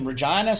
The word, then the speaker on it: Regina: I'm in Regina, Saskatchewan, and that's how you say it.